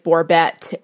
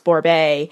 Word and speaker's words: Borbé: It looks like Borbet in the spelling, but it's pronounced Borbé.